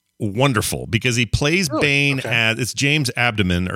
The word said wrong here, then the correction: Adomian: James Adomian.